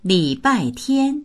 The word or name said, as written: li3bai4tian1